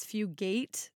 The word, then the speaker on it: Fugate: I think that's how you say his name. – Yeah, I'm pretty sure you're right that it's Fugate. And we we have a friend of the pod who is definitely a listener whose last name is Fugate. So I want to make sure we pronounce the Fugate to avoid any confusion.